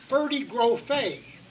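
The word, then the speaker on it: Ferde Grofé: It is pronounced Ferdy Grofe.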